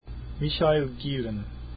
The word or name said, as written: gielen